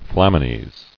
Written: Flamines

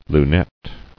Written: Lunette

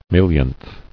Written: Millionth

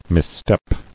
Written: Misstep

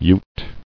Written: Utes

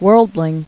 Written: Worldling

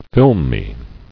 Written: Filminess

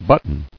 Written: Buttons

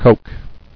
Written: Cokes

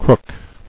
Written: Crooking

